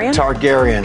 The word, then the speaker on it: Targaryen: Targaryen. – My gosh, sorry. – You Game watched of Thrones, it, right? – I did, yeah. But I just blanked out. – I get the Targaryens.